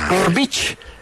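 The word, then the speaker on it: Grbić: Si se escribe G-R-B-I-C, brick. – No, por eso le dicen, y cuando, como termina en IC, croata, sí. Es itch. Gorbich. Gorbich.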